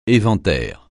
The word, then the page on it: éventaire: français